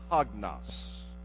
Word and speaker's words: hagnos: That Greek word pure is a word we pronounce in the Greek, hagnos. Hagnos.